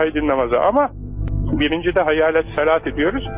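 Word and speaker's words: hayyâle salâti (حي على الصلاة): İşte bu T harfi geçildiği zaman T okunur, durulduğu zaman H okunur da ondan. Şimdi bunu bilmiyor. Efendim, kardeşlerimiz kâhmet getirirken hayyâle salâhi, hayyâle salâhi diyor. Hayır. Yani geçildiği zaman hayyâle salâti.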